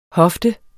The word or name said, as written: hofte